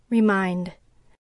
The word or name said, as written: remind